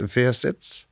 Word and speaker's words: Wirsitz: Well, the actual place he's from is, I don't know how you pronounce it, but Wirsitz, W-I-R-S-I-T-Z.